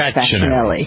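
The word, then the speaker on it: Fascianelli: He pronounces it the correct way, which is Fasanelli. – Yes. – Right. The C-I in Italian is pronounced like a C-H.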